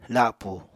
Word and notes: lapù: apatani

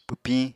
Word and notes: pwpiñ: Apatani

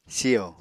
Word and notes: siyo: Apatani

